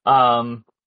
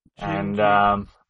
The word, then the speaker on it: Gingy: Jinji is his name on YouTube, Gingy, and. – Sure, it's not Kinsey. Did some... – No, because I don't know why you'd pronounce G-I-N as Gen.